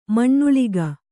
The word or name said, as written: ಮಣ್ಣುಳಿಗ